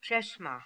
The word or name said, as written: Cheshma